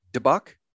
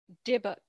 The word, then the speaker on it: dybbuk: Is it debuk? – Dybbuk. – Dybbuk, D-Y-B-B-U-K, in Jewish lore is a soul without a body.